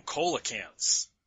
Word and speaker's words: coelacanth: says the monsters are descended from coelacanths. The word's pronounced coelacanth. I know it's spelled with a C, honey, but it's pronounced coelacanth.